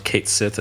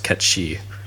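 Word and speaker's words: Cait Sith: So even though it's spelled Kate Sith, it's pronounced Ketshi.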